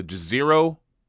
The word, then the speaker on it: Jezero: It's called the Jezero Crater.